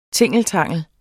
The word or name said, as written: tingeltangel